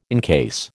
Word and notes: incase: American English

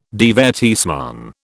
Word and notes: divertissement: British English

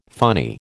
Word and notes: funny: American English

